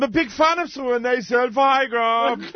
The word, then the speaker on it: Zellweger: It's Zellweger. That's who it is. – I'm sorry, that's Zellweger. – Zellweger! – Zellweger, okay.